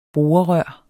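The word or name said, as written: borerør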